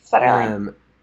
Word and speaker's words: Federline: Federline. – Federline.